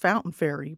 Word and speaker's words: Fontaine Ferry: I read about Fontaine Ferry Park, pronounced Fountain Ferry by locals,